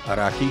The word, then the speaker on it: Araki: Araki.